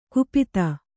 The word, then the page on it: ಕುಪಿತ: Kannada